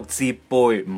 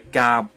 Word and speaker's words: 浃背: 喂，而家好多人都系读杯水车薪噶、哦，而汗流浃背咧，系一个读浃背，唔系夹。